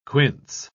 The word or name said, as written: quince